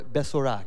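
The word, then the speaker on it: besorah: Now, the word gospel, the equivalent in the Hebrew language, is pronounced as biser or besora.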